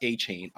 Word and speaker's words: Achane: Like, that makes too much sense to be pronounced Achane.